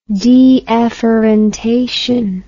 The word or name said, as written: deafferentation